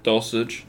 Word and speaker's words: Dulcich: Other than that, I'm, I'm rolling with Dolchik at that point. Is that how you say it? Dolchik? Dolchik? – Dulcich. Dulcich. Yeah.